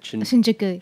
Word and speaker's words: Shinjuku: My first day, I was bombarded by the main square, Shinjuku. How do you say it? – Shinjuku. Mm-hmm.